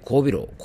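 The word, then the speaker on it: 後鼻漏: まあ、この季節に入ってからですね、えー、交尾楼。交尾楼というのはですね、後ろに鼻が漏れると書いて交尾楼と読むんですけども、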